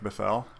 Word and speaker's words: Bithell: So Subsurface Circular, it's from the maker of Thomas Was Alone, Mike Bethel, and his game company, Bethel Games. It's spelled Bithell, which is really cool.